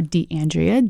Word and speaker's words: DeAndrea: So Instagram is Shana, S-H-A-N-A underscore DeAndrea, D-E-A-N-D-R-E-A.